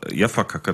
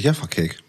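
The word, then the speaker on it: Jaffa Cake: Wir haben immer Jaffa-Kacke dazu gesagt. – Das ist Jaffa-Cake.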